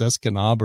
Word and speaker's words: Escanaba: I was like, oh, because they have a water tower in the middle of town that says S-E-S Canada. It's spelled Canada. I guess it's pronounced Escanaba or something.